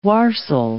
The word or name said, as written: warstle